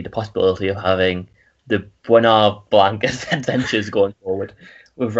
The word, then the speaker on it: Benoit: I'm going to go for Knives Out with Bernard Blanc. Bernard Blanc. How do you pronounce his name? – Benoit.